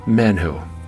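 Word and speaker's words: menhu (מן הוא): The Hebrew word for the phrase, "what is it," is pronounced menhu.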